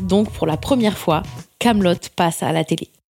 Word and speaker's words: Kaamelott: Alors très vite, ils se rendent compte que les gens appellent ça Kaamelott, et ça énerve Alexandre Astier, qui rajoute donc 2 A et 2 T afin que l'on comprenne bien que ça se prononce Kaamelott. Kaamelott.